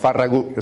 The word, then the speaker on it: Farragut: I know they pronounce it Farragut back in Washington. Farragut, it's a Catalan name.